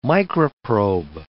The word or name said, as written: microprobe